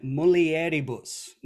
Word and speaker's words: mulieribus: So, Benedicta tu in mulieribus. Now, am I pronouncing that last one correctly? Because I had a lot of trouble learning that one. Mulieribus. Is that correct? – That's right. Correct.